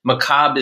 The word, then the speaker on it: macabre: but the little history lesson is that it's a French word. Macabre is the proper way to say it, except us Americans steal everything, brutalize it, and then normalize it.